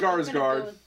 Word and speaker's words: Skarsgård: Skarsgård.